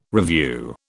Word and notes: revue: British English

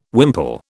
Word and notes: wimple: British English

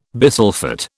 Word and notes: bisulphate: British English

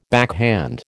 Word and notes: backhand: American English

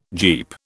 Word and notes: Jeep: British English

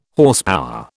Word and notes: Horsepower: British English

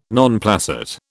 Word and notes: non placet: British English

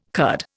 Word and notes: Kurd: Indian

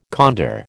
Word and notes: condor: American English

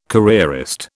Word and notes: careerist: British English

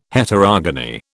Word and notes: Heterogony: British English

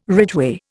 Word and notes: ridgeway: Indian

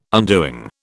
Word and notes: undoing: British English

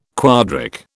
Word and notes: quadric: British English

